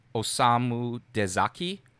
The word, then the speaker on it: Osamu Dezaki: This includes Masao Maruyama, Osamu Dezaki, Rintaro, which is a pseudonym for Shigeyuki Hayashi, and also Yoshiaki Kawajiri.